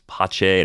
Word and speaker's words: Pache: It's Pache, and it's a really unique name to pronounce. Just doesn't roll off the tongue.